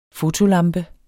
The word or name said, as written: fotolampe